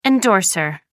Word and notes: endorser: American English

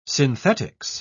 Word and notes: synthetics: British English

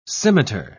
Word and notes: scimiter: British English

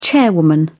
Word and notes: chairwoman: British English

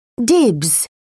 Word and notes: dibs: British English

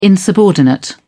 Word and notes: insubordinate: British English

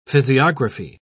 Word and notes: physiography: British English

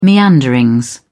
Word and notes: meanderings: British English

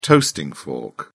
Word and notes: toasting fork: British English

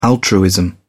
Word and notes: altruism: British English

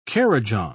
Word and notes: kurrajong: British English

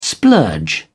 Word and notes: splurge: British English

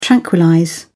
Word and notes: tranquillize: British English

